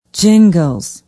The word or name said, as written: Gingles